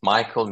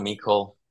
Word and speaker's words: Micol: Yeah, as you want. Micol, Micol.